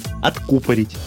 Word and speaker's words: откупорить: Когда выбрали напиток, нужно открыть бутылку, а если сказать элегантно, то откупорить, то есть выдернуть пробку, по возможности с ударением на «у».